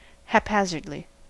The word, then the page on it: haphazardly: American English